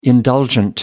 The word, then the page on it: indulgent: American English